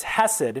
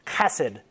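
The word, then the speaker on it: hesed (חסד): It's hesed. And you actually pronounce it like you've got something in your throat, like hesed.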